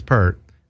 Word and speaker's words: Peart: and he's he's saying it's Pert.